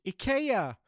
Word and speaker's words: IKEA: It's a fairly well-known Swedish home retailer furnisher called IKEA.